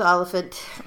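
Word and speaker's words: Olyphant: It's O-L-Y-P-H-A-N-T. – I'm going with Oliphant. – Okay. Like elephant. – Like elephant, but with an O.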